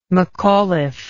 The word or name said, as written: mcauliffe